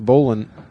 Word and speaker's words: Boleyn: I'm pretty sure it's pronounced Boleyn.